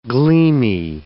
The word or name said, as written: gleamy